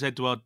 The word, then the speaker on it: Edouard: Okay, so Owen Hazen says, why was Edouard dropped? – That's how you pronounce that, is it? – Yeah.